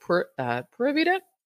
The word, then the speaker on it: Pura Vida: They say it's Pura Vida, but it's pronounced Pura Vida.